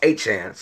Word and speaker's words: Etienne: Now, people call him Etienne, but, you know, we're from Louisiana. It's pronounced Etienne.